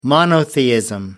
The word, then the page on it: monotheism: American English